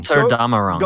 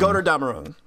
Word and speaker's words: Götterdämmerung: Like, also like Erase, like I Caught You, was it Goddard? I'm gonna mispronounce it. It's the German one. – Götterdämmerung. – Götterdämmerung.